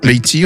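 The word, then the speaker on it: Bekhti: je ne sais jamais comment on le prononce réellement, mais c'est Leïla Beïti, je crois que ça se prononce. – Leïla Beïti. – Beïti, ouais.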